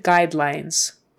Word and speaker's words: guidelines: Brand, brand, brand guidelines, guide, forget the U, it's a long I, guide lines. So two long I's in those two syllables, guide lines, and the S at the end is a Z, guidelines, guidelines, brand guidelines.